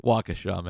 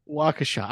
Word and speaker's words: Waukesha: Waukesha. – Waukesha.